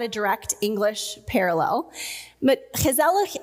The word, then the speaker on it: gezellig: gezellig. Now, the Dutch hard guttural G makes that word a little difficult to pronounce for us Americans, but it's spelled G E Z E L L I G, gezellig.